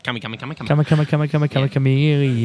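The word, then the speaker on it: Kamikamica: Kamakamaka, however say his name. – Tui Kamakamika. – Kamakamika. Yeah, him.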